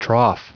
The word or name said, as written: trough